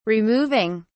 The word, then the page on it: removing: British English